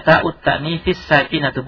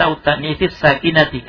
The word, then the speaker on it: تاء التأنيث الساكنة: Ya, bacanya ta'ut ta'nifis sakinatu, bukan ta'ut ta'nifis sakinati. Kenapa? Karena sakinatu itu na'at terhadap ta'. Ta'ut ta'nifis sakinatu. Jadi, dhamma sakinatu mengikuti dhammanya ta'u. Bukan mengikuti kasrahnya ta'nif.